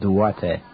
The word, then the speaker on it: Duarte: Duarte.